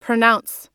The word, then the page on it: pronounce: British English